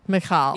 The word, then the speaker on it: Michal: Michael. – Yes. – Or Michal. I don't know how it's said. – Michal. Yes.